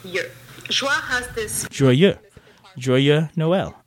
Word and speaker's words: Joyeux Noël: Today we're going to learn how to pronounce Joyeux Noël in French.